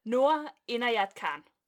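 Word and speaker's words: Noor Inayat Khan: Nora Inayat Khan, tror jeg nok, det udtales, ikke? Jo. In, Inayat. Jeg er ikke så god til indisk.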